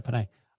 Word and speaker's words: Penei: Penei, why did I pronounce it that way? – That's right, Penei.